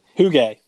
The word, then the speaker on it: Hygge: Hyg. – Oh yeah. No. Yeah, yeah. – Hygge. – Yes, Hygge. That's how you say it. It's spelled like hyg.